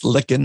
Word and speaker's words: Lykken: So it's Lykken, not Lykken. And but a lot of people mispronounce it.